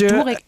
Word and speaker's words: Durek: Derek Verrett. Heißt er so? Ja, also schreibt sich Durek. – Durek Verrett wird da, glaube ich, ausgesprochen.